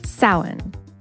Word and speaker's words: Samhain: Say it with us Samhain. That's the correct pronunciation of the word spelled S A M H A I N.